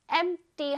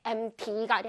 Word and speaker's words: empty: Empty 할때 empty가 아니라 empty, D 발음으로 해주세요.